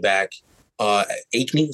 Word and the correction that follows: Achane: is that how you say his name? De- De'Von Achane. And then they got Raheem Mostert. Achane, Okay.